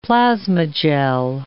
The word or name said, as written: plasmagel